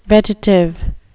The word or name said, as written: vetitive